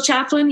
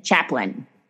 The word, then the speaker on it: chaplain: Hospital chaplain, yes. – Chaplain. So the L-A-I-N is an in, chaplain.